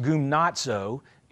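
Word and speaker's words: gymnazo (γυμνάζω): And the word in Greek is pronounced gymnazo. It's actually, it looks like gymnazo. G-Y-M-N-A-Z-O. It's pronounced gymnazo.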